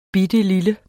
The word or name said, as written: bittelille